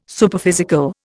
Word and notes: Superphysical: Indian

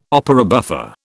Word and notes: opera buffa: British English

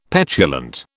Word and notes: petulant: British English

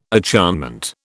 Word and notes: acharnement: British English

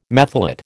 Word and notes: methylate: American English

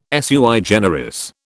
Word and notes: sui generis: British English

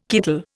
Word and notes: kittle: Indian

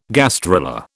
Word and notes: gastrula: British English

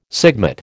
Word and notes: sigmate: American English

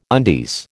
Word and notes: undies: American English

